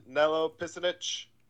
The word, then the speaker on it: Nello Picinich: So today on the podcast, we have a guest, Nello Picinich.